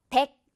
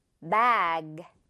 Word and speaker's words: bag: 이거 백 아니고 bag.